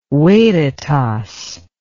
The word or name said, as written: veritas